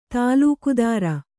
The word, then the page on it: ತಲೆಕೆದರು: tale kedru